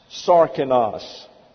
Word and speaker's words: sarkinos: It is followed by the predicate nominative adjective of sarkinos. S-A-R-K-I-N-O-S, pronounced Sarkinos.